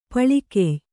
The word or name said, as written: ಪಾಳ್ಗೆಯ್